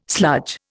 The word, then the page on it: sludge: Indian